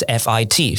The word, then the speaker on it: fit: 好，或是类似像是 fit 好跟 feet 好，一个是 f i t fit，一个是 f e e t feet。